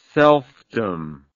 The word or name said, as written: selfdom